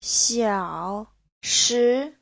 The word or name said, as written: xiao3shi2